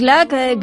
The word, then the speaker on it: Glück: Soy Louise Gluck. Gluck se escribe con una U con diéresis y el apellido es de origen húngaro.